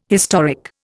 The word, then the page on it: historic: Indian